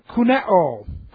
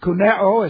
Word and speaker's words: kuneo: Then we have the root word kuneo. Kuneo. And kuneo is K-U-N-E, long O. Kuneo.